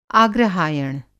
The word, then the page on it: आग्रहायण: Hindi